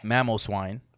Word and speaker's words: Mamoswine: Mamoswine and Sylveon.